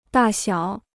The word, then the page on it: 大小: dà xiǎo